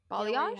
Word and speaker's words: balayage: but she definitely has like a – I never know how to pronounce this, like a balayage. – Balayage? – Have like, yes, balayage.